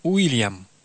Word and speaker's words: Уильям: Что касается английского «we», «w» в имени «Вильям», то оно произносится англичанами как «у», «Уильям». Таким образом, костяк устойчивых согласных в обеих именах один и тот же.